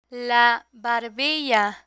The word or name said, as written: la barbilla